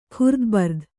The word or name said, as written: ಖುರ್ದುಬರ್ದು